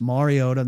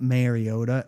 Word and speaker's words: Mariota: It's Mariota, not Mariota. And he won a Heisman Trophy and has become a good player in the league. People should know how to pronounce it.